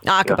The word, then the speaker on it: Aqaba: You had mentioned that before, that you think the Exodus crossing was not the Gulf of Suez, but the Gulf of Aqaba. Is that how you pronounce it? – Aqaba. – Yeah. Sorry, my Arabic isn't so good.